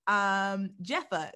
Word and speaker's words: Jepha: And today we're joined with a special guest, Jepha. Is that how you pronounce it? – Yes, Jepha. – All right, dope. All right, so Jepha, let's tell us a little about yourself.